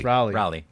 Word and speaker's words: Raleigh: Raleigh.